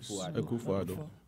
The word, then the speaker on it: Akufo-Addo: Yes, Akufo-Addo.